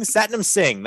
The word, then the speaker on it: Satnam Singh: Satnam Singh. That's the proper way to pronounce his name.